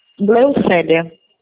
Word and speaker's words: Gleucélia: Eu falo com o Glaucilia, é isso? Falei certo? – Gleucélia.